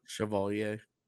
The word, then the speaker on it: Chevalier: Chevalier. Did I pronounce that correctly? – Chevalier.